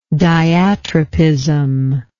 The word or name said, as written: diatropism